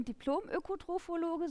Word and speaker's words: Diplom-Ökotrophologe: Also ich habe bin Diplom-Ökotrophologe, so wird es ausgesprochen.